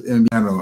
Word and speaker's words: invierno: Genau, eigentlich wird es invierno ausgesprochen, invierno, ja, also das W, das wird wie invierno, wie ein B eigentlich, invierno ausgesprochen. Das B wie ein B, bei dem die Lippen sich nicht berühren, zum Beispiel trabajar, trabajar, invierno, trabajar.